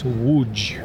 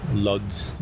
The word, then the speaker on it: Łódź: However, this last Z has a comma over it, which makes the sound G. So it's Łódź. – And there's no way an American who doesn't understand Polish would be able to pronounce that word. – You would see that as Lodz.